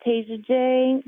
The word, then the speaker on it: Tasia Jae: You can find me on YouTube at Tasia Jae. That is the name of my channel. It's spelled TASIAJAE.